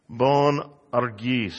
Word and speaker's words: Boanerges: of whom he gave the name Boanerges. Just a little joke here. I worked really hard on trying to get the proper pronunciations, and I just messed it up.